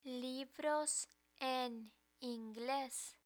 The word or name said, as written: libros en ingles